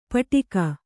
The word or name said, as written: ಪಾಟೀಕ